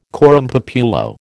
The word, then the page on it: coram populo: American English